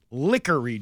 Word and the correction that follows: LaCoury: Drive until somebody finally corrected him because we went back and looked. He's now pronouncing it LaCoury Drive, but he was saying Lickery. – Yeah, right. – For a good half hour before somebody said, hey, it's LaCoury.